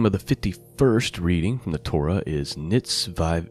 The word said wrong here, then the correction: Nitzavim: Nitzavim. I was pronouncing that wrong to begin with. – Nitzavim,